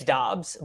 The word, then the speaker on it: Dobbs: Cool with Dubs here? – Yes. I'm cool with Dubs here, but is Kmet gone? – Yes. – Okay. – I don't know how to say it. – It's Dobbs, but do not listen to me for how to pronounce names.